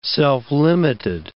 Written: self-limited

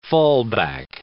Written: fallback

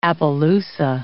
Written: appaloosa

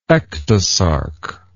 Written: ectosarc

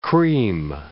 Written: cream

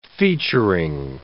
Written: featuring

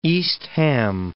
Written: East Ham

